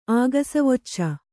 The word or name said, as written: ಆಗಸವೊಚ್ಚ